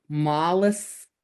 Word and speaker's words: Moleskine: I have a whole bunch of, I think it's pronounced Moleskine notebooks. I found out I was pronouncing it wrong.